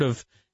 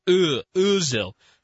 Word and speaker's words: Özil: You had the umlaut over the O. So you had, it's not O, it's Özil.